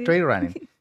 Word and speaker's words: trail running: Y tú eres muy fanática del trail running, ¿está bien? ¿Lo dije bien? Sí, el trail running.